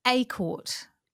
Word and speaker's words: A'Court: It's A'Court, like acorn, but with a T.